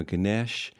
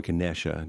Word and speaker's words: Ganesh: Now, Ganesh, or Ganesha, depending on how you say it or where you're from, is the provider and remover of obstacles.